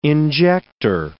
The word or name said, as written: injector